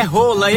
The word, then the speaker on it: Haaland: Pronúncia do nome Haaland não é desse jeito não. No país dele é rola, eita que situação.